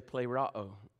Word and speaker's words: pleroo: It's P-L-E-R-O-O. P-L-E-R-O-O. And I think you're going to say pleroo, but it's not pleroo. But it is actually plerao, and it's a word that means fulfill.